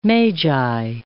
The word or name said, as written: magi